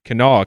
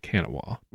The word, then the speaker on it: Kanawha: Apparently, it's Kanawha County, not Kanawha.